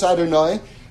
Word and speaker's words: Adonai: 112 is the gematria of Aleph, Dalet, Nun, and Yud, which is the name of Hashem in this realm, pronounced Adonai.